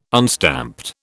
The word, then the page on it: unstamped: British English